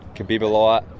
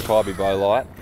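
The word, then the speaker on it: Kybybolite: There's been Kibibolite, Kybobolite, it's Kybibolite. Everyone calls it Kybi, though.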